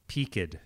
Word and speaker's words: peaked: Are you saying peaked or peaked? It is spelled the same as peaked, but it is pronounced with two syllables.